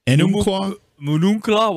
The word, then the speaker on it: Enumclaw: We've got, I don't know how to pronounce that, Enumclaw. – Enumclaw, Washington.